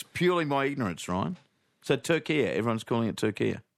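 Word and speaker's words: Türkiye: But in yesterday's Socceroos update, you kept saying Turkey and not – Turkia. – Turkia. What's doing? I'd never heard of Turkia, Ryan, is my honest answer. Yeah. Never, ever, ever heard of it pronounced that way.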